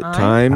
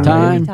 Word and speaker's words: thyme: Is it thyme? – Thyme. All right. They spelled it wrong. – It has an H in it. – It does. – It's just silent.